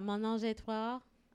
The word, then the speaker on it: Ménage à Trois: It's a Ménage à Trois.